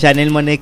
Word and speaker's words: Janelle Monáe: Janelle Monáe, que pronunciamos bien su nombre.